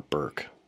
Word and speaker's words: Bork: His name was Justin Christian Bork, or Burke. I guess his father pronounces it that way, so I will too. It looks like Bork, like Ray Bork.